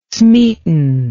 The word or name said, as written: smeaton